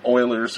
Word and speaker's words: Euler: They're related indirectly through a thing called Euler's equation, which it talks about. It's spelled E-U-L-E-R or something like that. It's actually pronounced Euler.